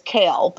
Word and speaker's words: Cole: This is a running thing, but my sister married a man whose last name is Kale, but it's spelled Cole.